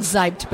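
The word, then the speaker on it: Seibt: And you pronounce it Zeibt, but it's with an S.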